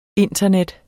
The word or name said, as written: internet